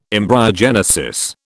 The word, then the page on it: embryogenesis: British English